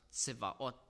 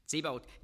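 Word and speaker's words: Zebaot (צבאות): Das heißt, modern Hebräisch spricht man es aus, Zebaot. Zebaot.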